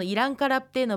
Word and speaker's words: イランカラプテ: テキストの中で小さなプがあると思うんですけれども、このイランカラプテのプはプーと言わずに、ただ口を閉じるようにして発音します。